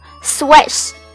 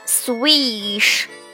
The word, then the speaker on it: swish: 这个词特别要注意字母 i 的发音，它应该读作短音 a a swish swish，不要读成了 swish，这样是不对的哦。